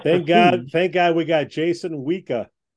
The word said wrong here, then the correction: Wisha: Did I say his name right again? – Wisha. – Wisha, damn it.